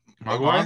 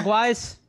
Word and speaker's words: Mogwai: Mogwai? Mogwais? Mogwais, thank you. Is that how it's pronounced? – Mogwai, like, yeah. yeah. Mogwai.